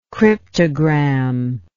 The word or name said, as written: cryptogrammatist